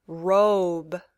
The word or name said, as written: robe